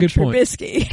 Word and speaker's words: Trubisky: Oh, but we do know is that there's no T in his last name, and Matthew's not here to butcher that, so it's Mitchell Trubisky.